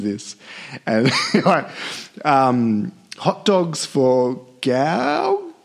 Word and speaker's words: Gauguin: Hot dogs for Gauguin? – I say Gauguin. – You actually say Gauguin. – I think I might have had an extra syllable in there.